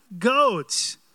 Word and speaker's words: goads: It was this: Saul, Saul, it is hard for you, an unusual word's coming up, to kick against the goads. What kind of word is that? Goads, G-O-A-D-S, rhymes with toads.